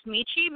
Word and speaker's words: Michiru: No, actually, I think Michiru's name is just pronounced Michi because I think the RU is generally not pronounced when it's at the end of names.